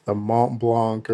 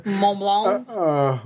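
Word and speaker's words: Montblanc: And it's kind of, you know, besides Google and Samsung, you know, we've had the Mont Blanc or whatever. – Mont Blanc? – Whatever. – Just don't pronounce half of the letters and you'll get it right.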